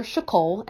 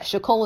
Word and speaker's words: Shicole: I have another Twitter handle called Gardener Shacole, and that Shacole is spelled S-H-I-C-O-L-E, is my middle name.